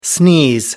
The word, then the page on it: sneeze: American English